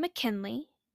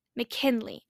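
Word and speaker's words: McKinleigh: For example, and this is a mild version or a mild example, they'll take the name McKinley and they'll put M C K I N, and you're thinking, great, all normal, all great and then they'll end it with L E I G H. McKinley. And phonetically, yes, I can see how they got there.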